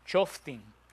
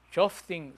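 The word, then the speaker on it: Shoftim (שופטים): ¿Cómo se dice el libro de los jueces en hebreo? Shoftim. Shoftim, con M al final. Shoftim se dice el libro de los jueces.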